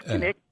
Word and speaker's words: Kinnick: Do people say Kinney because they just don't want to say Kinnick, Kinnick? – I think that we're all afraid that we might pronounce it incorrectly, but you're right. It's Kinnick, Kinnick.